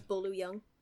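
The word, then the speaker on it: Bolo Yeung: It is Bolo Young.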